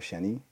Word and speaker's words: Sheny: At DJ underscore Sheny. So S-H-E-N-Y.